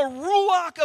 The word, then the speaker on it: ruach: Ruach, kind of like woo-ha, but ru with like R in the front of it.